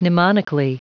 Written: mnemonically

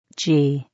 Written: g

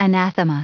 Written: anathema

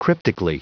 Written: cryptically

